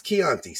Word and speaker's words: Chianti: It's Chianti.